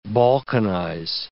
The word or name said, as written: balkanize